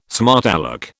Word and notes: smart alec: British English

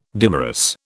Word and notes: dimerous: British English

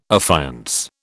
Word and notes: affiance: British English

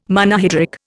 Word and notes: Monohydric: Indian

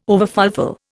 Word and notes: overfulfil: Indian

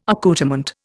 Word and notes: accoutrement: Indian

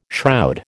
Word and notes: shroud: American English

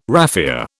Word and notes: raffia: British English

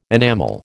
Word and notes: enamel: American English

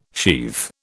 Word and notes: sheathe: British English

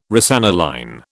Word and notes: rosaniline: British English

